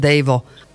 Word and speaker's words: D3JVO: Počuj, ty máš aj taký pseudonym, no neviem ho presne, ako sa vyslovuje, tak musíš nám povedať, ja len tak šípim, či to nie je Dejvo. Áno, je to správne, je to Dejvo.